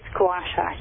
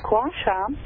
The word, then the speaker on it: gua sha: Do you pronounce it as gua sha? – It's gua sha, yes. – Gua sha, it's one of my least popular things that I offer to the patients, because it's a little bit uncomfortable.